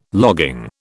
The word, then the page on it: logging: British English